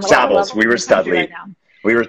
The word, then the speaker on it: Savills: It's not pronounced, and pardon if anybody is offended, it's not pronounced syphilis. That is a sexually transmitted disease. It's called Savills.